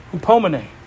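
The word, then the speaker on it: hupomene (ὑπομονή): You see, patience in the Greek word, I think I got this one pronounced right, hupomene. Hupomene. Yeah, I could smile. I'm smiling under my mask. I'm like, yes! I said it right. I pronounced it right.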